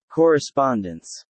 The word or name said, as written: correspondence